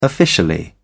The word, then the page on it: officially: British English